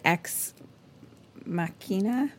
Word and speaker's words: Ex Machina: I don't know how to say the word ex machina. – Ex machina. – That's what it is.